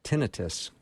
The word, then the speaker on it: tinnitus: A listener just jumped in and wants to know, does your book cover chronic tinnitus or tinnitus? I don't never know quite how to say that. – Tinnitus is actually the right way to say that.